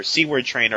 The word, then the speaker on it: Seward Trainer: We also got the first mention of Seward Trainer, or Seward Trainer, I guess we got to pronounce his name, who I believe was named after Howard Mackie's father-in-law.